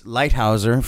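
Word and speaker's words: Leithauser: Now, we tried to get Hamilton Lighthouser. It's Lighthouser from The Walkmen.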